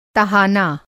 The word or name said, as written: तहाना